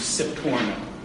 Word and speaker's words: Sforno: The correct pronunciation is Sforno. Sforno should be Sforno. Okay, it's the name of a city in Italy.